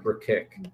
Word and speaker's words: Brkic: Okay, I mean, this is how his last name is spelled: Brkic. So it's like brick, but Burkick.